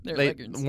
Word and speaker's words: Leghorn: Leghorns. – Warner Brothers just didn't get it right. – Oh, no. It had to sound like Foghorn, Leghorn, I guess. Leghorn is how you pronounce it, yeah.